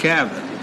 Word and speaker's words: Cavan: That's pronounced Cavan. – And why? – Cavan is it's from the Irish, from Ireland, named after a town in Ireland called Cavan.